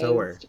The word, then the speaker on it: sower: S-O-W-E-R. I just realized. – Sower, probably. – Because it's referring to sowing of seeds. – Yeah, probably sower. – Parable of the Sower. – Yeah. – Wow. I'm embarrassed that I've been pronouncing that incorrectly this whole time. – That's okay. Everyone probably just thought it was spelled S-O-U-R.